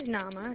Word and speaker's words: Nama: Okay, so my name is Nama. It's spelled N-A-M-A. – N-A-M-A or N-A? – N-A-M-A.